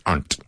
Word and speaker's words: aren't: And I'll make aren't one syllable, aren't.